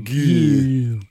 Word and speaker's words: Gue: Well, it's more like new you from the, It's like G-N-U? – G-U-E. – G-U-E. – Goo. That's how they pronounced it in the movie.